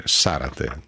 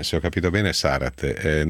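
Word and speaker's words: Zárate: E ringrazio Maria Angelica, tifosa sudamericana, che con molto affetto scrive, suggerisce la corretta pronuncia del cognome Zarate, o Zarate, raccomandando di mettere l'accento sulla prima A e di pronunciarla Z dolce, e quindi Zarate, credo. Se ho capito bene, Zarate, non è la mia specialità, non potrò mai fare concorrenza ai telecronisti di Sky e altrove, però ci provo.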